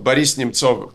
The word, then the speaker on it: Boris Nemtsov: My good friend, Boris Nemtsov, Boris Nemtsov in Russian pronunciation, was murdered in 2014, also in February, 10 years ago.